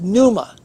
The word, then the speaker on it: pneuma: The word is pneuma, spelled with a P.